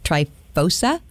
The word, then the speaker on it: Tryphosa: Tryphena and Tryphosa? – Yes, I was assuming that's how you say it.